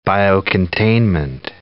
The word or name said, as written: biocontainment